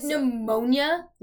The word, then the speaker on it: pneumonia: Like, pneumonia? Like, why does it have a P? Why does it have a silent P?